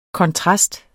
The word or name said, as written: KONTRASTER